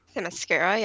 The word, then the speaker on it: Themyscira: Themyscira, yep.